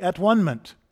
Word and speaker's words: atonement: And there you have the melody of the word atonement, the music behind the word, because we pronounce it wrong, right? It should be atonement. That's where the word comes from, and that's what it means.